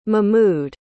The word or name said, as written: mehmood